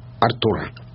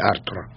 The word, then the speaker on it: Артур: До 7 лет Мерлин воспитывал Артура. Вообще, точнее, Артура. Ну, не является ошибкой, это русское ударение, а это принятое в Англии.